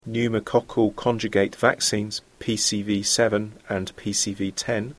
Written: pneumoccal conjugate vaccines

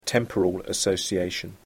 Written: temporal association

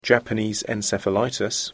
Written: Japanese encephalitis

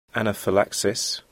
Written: anaphylaxis

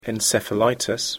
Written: encephalitis

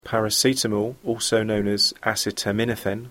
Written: paracetamol

